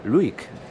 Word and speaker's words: Lewek: Vamos a hablar en primer lugar de la soprano norteamericana, la soprano estadounidense Catherine Luick, que se escribe su apellido Lewick, L-E-W-E-K, pero se pronuncia Luick, Catherine Luick.